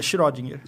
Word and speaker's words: Schrödinger: Como é que fala? Schrödinger? É Schrödinger. Schrödinger. Schrödinger.